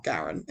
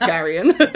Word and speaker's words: Garion: I'm going to be honest, I mispronounced the main character's name for like the first 10 years I was reading them. And then I realized there was an I in it. And I was like, oh, it's not Garen, it's Garion.